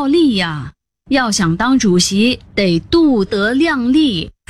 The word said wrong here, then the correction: 度: 哎，不是度，得读作夺。 – 为什么？那不是度数的度吗？ – 多音多义字，读作夺是个动词，意思是揣测估计。